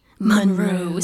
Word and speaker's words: Munroe: I'm saying it, if it sounds like I'm saying it weird, it's because it's spelled M-U-N-R-O-E. Monroe. See, thank you.